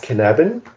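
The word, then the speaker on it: Cynefin: Ja, also ich arbeite gern mit einem Framework, das heißt Cannabin. Bitte nicht googeln, das schreibt man so anders, als man sagt, ist nämlich ein walisisches Wort von Dave Snowden.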